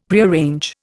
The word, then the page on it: Prearrange: Indian